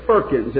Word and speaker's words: firkins: Everybody say firkins. It rhymes with perkin.